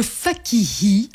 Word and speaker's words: Fakihi: Là, Oui, mais bravo, hein, parce que le Fakihi, souvent les gens vont soit le prononcer comme Fakini, Fakiri, donc pauvre lettre N, parce que cette lettre apparemment est difficile à prononcer pour certains, mais tu prononces très très bien mon nom.